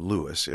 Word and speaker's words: Louis: And that's the reason why he always pronounced his name Louis, as we can hear on his record of Hello, Dolly.